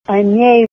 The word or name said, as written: ane